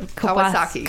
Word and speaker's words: Kawasaki: Is that Kawasaki? – Kawasaki. I knew I was going to say it wrong. – Just like the motorcycle.